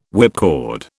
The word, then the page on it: whipcord: British English